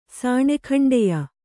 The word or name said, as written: ಸಾಣೆಖಂಡೆಯ